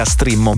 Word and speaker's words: Streammo: gratuita Strimmo, mi raccomando, perché si scrive Streammo,